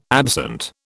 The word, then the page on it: absent: British English